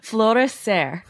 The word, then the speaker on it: Florescer: Florescer. – Florescer.